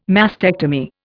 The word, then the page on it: mastectomy: Indian